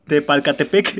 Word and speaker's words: Tepalcatepec: Entonces, la ruana levantó primero y Tecaltepec, te Tepalcatepec. ¿Dónde está, güey? Le siguió ah. unas horas después. Al principio solo eran 14, eran personas las que iniciaban la revuelta. En Tecaltepec, Tepalcatepec, chingado.